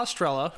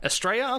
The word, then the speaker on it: Estrella: Estrella. – Estrella? Okay, fair enough. That's good enough for me. That made sense. – Estrella. No R. That's on me. Estrella.